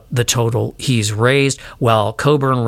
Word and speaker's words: Cockburn: and Yale graduate and author Leslie Coburn. And it's spelled Cockburn, but I'm just going to say it's Coburn because of Bruce Coburn, the artist.